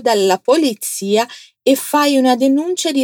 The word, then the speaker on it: polizia: Allora vai dai carabinieri o dalla polizia. Agora fala para mim só polizia, pronunciando a letra Z como se tivesse um TS ali.